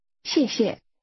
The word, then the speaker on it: 谢谢: 谢谢. 谢. 谢谢。谢谢。 – That's pronounced with two falling tones.